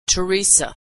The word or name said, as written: Theresa